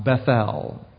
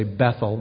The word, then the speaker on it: Bethel: And it says he awoke, and he named that spot Bethel, or we like to say Bethel, but it's more correctly pronounced Bethel, which means the house of God.